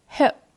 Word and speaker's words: hurt: 那这句粤语里，它的 hurt 也是伤害的意思，发音跟 cut 原理一样，它夹在粤语句子中使用时，自然就变成了粤语的发音 hurt。